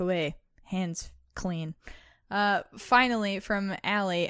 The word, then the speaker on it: Ali: I know somebody really, really close to me that spells it A-L-I and it's pronounced Allie.